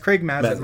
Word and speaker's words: Mazin: I remember in the they do with Neil Druckmann and Craig Mazin, is that how you say it? – I think it's Mazzini. But yeah. – They do like the little talking head stuff. – Oh, sorry. It's Craig Mazin.